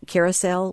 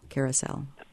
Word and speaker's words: Kerasal: And that's Caracel, which I mentioned earlier, the salicylic acid ointment. – How do you spell that? – K-E-R-A-S-A-L, Caracel.